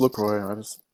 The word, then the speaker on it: LaCroix: Yeah, but I think you're supposed to pronounce the X. – Are you? Because I thought I looked it up a while back and it said LaCroix. – No, it's LaCroix.